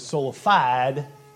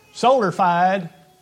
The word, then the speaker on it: sola fide: If you go to a cross-training place, you may have heard it mispronounced as sola fide. Solar fide. Sola fide is a Latin term.